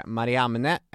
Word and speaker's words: Mariamne: Den fru som hade gett honom det här anspråket på tronen då, hon hette Mariamne, eller Mariamn, om ni vill uttala det så, och kom alltså från de Hasmoner som hade bossat i Judén.